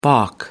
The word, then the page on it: baulk: American English